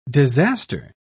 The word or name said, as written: disaster